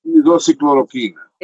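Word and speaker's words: hidroxicloroquina: Eh, disculpe que no, lo, no el término no lo pronuncio bien, pero hidro... ¿Cómo usted decía? ¿El hidro qué? ¿Y la citromicina? – Hidrocicloroquina. – Entonces, e, este es más abundante que, ese, que esos dos.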